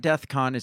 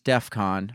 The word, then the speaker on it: DEFCON: Twitter locked his account on Sunday after it removed one of his tweets saying that he was saying he was going DEFCON 3 on Jewish people. – What does that mean? – I don't know. First of all, it's not DEFCON, it's DEFCON.